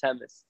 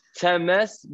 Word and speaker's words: Temes: So yeah, it's pronounced Temes. – Yeah, I'm pronouncing it like an English word. – Yeah, it's all good. Temes, but I'm not a mess.